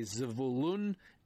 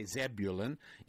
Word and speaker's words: Zebulun: But Zebulun and Issachar, or as I would say, Zevulun and Issachar, notice that in Hebrew the emphasis goes on the second part of the word. So in English we say Zebulun. In Hebrew, Zevulun.